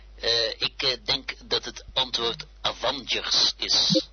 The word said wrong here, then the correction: Avengers: Avengers. Ja, ik weet niet hoe ik dit nou moet gaan doen. Nou, ik zal niet te veel muggen ziften. Het is eigenlijk niet helemaal goed uitgesproken.